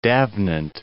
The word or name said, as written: D'Avenant